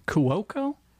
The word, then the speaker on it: Cuoco: Is that how you say it? – Yeah. – Cuoco? – Yeah.